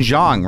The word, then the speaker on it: Jeong: So Ken Jeong, right? – Yes. – That's how you pronounce it. – Yes.